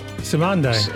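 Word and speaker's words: Cymande: Incredible Bongo Band, Ike Turner and the Kings of Rhythm, Skull Snaps, George and Gwen McRae, Instant Funk, Commande, how do you say that? Simand? – Simande. – Simande.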